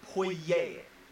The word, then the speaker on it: Poirier: Poirier, by the way. It's not Poirier, it's Poirier. – However you want to pronounce Poirier. It. Poirier. – Poirier. – We make our pronunciations. – Poirier. It's Poirier. It's Poirier. – It's a French. It's French origin, people.